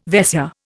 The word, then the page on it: Vaisya: Indian